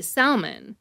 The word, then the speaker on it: Salmon: Salmon, an American veterinarian and pathologist who identified this genus of bacteria, and his last name is pronounced salmon.